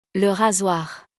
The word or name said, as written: Le rasoir